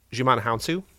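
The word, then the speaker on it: Djimon Hounsou: Digimon Hounsou? – Yes, thank you.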